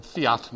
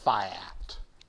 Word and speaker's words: fiat: The last one is fiat money, or the way we used to pronounce it, fiat money. Now they pronounce it fiat.